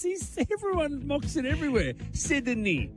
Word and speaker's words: Sydney: Sydney. – No, he took one out. – No, play it again. – Say the word Sydney. – Sydney. Two syllables.